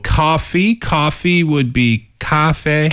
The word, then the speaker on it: 咖啡: It's the tones. – Yeah. – It's a little higher, right? Cafe, yes. – cafe. And it's the same tone for the first syllable and the second syllable.